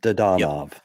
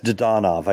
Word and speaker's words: Dadonov: Is that how you say his name? – Dadonov. – Is it? – Yeah, it's Dadonov. I called yesterday to confirm, because it's been pronounced every which way, but Dadonov.